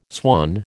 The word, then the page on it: swan: American English